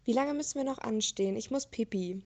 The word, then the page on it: anstehen: German